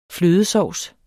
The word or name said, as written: flødesovs